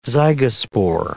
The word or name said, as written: zygospore